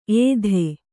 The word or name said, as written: ಏದು